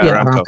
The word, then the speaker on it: Aramco: Aramco? Right. – Yeah, Aramco.